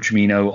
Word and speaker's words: Giacchino: So I quite like it's Michael Ch- uh, Camino. Ch- ah, I can never say his name. Michael Giacchino on the Rogue One one, and I can't remember who does.